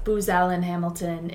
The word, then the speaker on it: Booz Allen Hamilton: Looking a little bit forward, you were also a senior consultant at Booz Allen Hamilton in Washington, D.C. I feel like I pronounced that wrong. No, you got it.